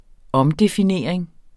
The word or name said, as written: omdefinering